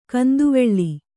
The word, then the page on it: ಕಂದುವೆಳ್ಳಿ: kannada